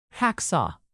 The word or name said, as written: hacksaw